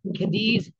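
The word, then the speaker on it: Cádiz: I am in Caddies. Oh God, if I only care to look up where I go. I'm in Cadiz. That's how you say it.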